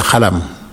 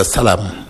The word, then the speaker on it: xalam: Et en wolof, tu prends un exemple, un groupe de musique qui s'appelle le « khalam », qui s'écrit « X-A-L-A-M ». Donc, au Sénégal, on dit le khalam. Et ici, en France, tu écris ce mot, tu donnes à quelqu'un de lire, il va dire le salam.